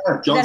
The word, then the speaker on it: Cena: He then went on to wrestle on TV with WWE as Mason Ryan, where he wrestled with the likes of John Cena, right? Cena? – Yeah, John Cena.